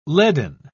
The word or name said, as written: leaden